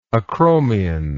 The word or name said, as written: acromial